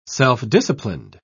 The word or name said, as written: self-disciplined